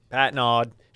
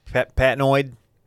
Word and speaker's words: Patnod: What would be the alternative pronunciation? Patnoid. – I'd say Patno or something like that. – Patno. – Like the D is just silent.